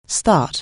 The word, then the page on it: start: anglais